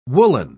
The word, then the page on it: woolen: British English